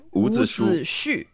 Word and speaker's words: Wu Zixu: Um, there's the one of uh, Wu Zixu. Did I say that right? Wu Zixu. Yeah, close enough. – Wu Zixu. Shu. Right. Okay.